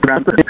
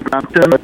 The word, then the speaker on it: Brampton: and I believe if I'm going to pronounce it right, the name of the city is Brampton, correct? – You got it.